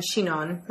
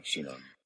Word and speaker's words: Sinon: Because they, you know, it By the way, you are pronouncing it correctly. I've looked that up. – Okay. – That's the correct. It's spelled S-I-N-O-N. – O-N, but it's Shinon. – It's pronounced like Shinon.